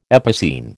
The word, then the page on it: epicene: American English